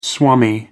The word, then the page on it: swami: American English